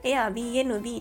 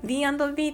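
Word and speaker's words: Airbnb: 帰省の際に途中で一泊しましたっていうお話で、ゲストハウスの予約ができるサイトを私はひたすらエア BNB って言ってたんですけれども、あれね、 B&B って読むそうです。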